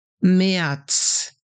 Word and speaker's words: März: Jetzt sprichst du das R wie ein A. Dann haben wir März. So, the Z at the end is pronounced like TS. März.